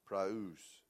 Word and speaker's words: praus: Gentle is actually a word that's pronounced preus. Praus.